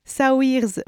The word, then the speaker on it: Saoirse: alors je vais le dire à la française, Saoirse Ronan, mais alors, je crois que ça se dit Saoirse. – D'accord, il y a une légère différence entre la prononciation française et la prononciation... – Mais les Irlandais, je ne comprends pas comment ils fonctionnent. Mais... Donc oui, voilà, mais à la française, ce serait Saoirse Ronan, donc rien à voir, qui a d'ailleurs participé à la production du film, tant la mise en image de ce récit l'a bouleversée.